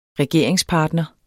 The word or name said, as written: regeringspartner